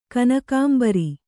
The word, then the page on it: ಕನಕಾಂಬರಿ: kanakāmbari